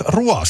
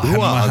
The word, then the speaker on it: Roy: No, hän tietysti Patrick, siis Rua, siis tämä vanhan liiton Patrick Roy. – Rua, hän mainitaan, siis lausutaan sen takia, koska hän taitaa olla sieltä Ranskaa puhuvat alueelta.